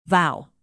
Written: vow

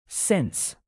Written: since

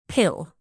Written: pill

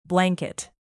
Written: blanket

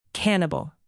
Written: cannibal

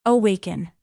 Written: awaken

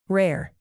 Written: rare

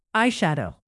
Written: eyeshadow

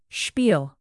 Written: spiel

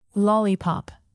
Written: lollipop

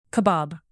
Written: kebab

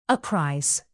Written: apprise

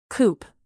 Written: coop

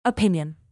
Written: opinion

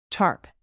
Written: tarp

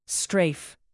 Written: strafe

